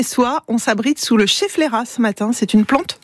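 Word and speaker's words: schefflera: Bon, au moins s'il pleut, on est à l'abri avec le chefflera. – Vous l'avez bien prononcé, le chefflera qui est une plante d'intérieur et on l'appelle la plante parapluie.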